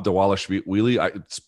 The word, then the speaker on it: Dvalishvili: Marab Dvalishvili. Spelled Dvalishvili. I'm sure that the V is pronounced like a W.